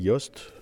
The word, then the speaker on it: Jost: Et l'année dernière, le principal financeur du projet s'est retrouvé en prison parce que c'était un gros transporteur routier qui s'appelle Yost, ça s'écrit J-O-S-T, oui, on voit des camions un peu partout de lui, donc c'est un des plus gros transporteurs européens.